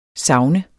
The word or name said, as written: savn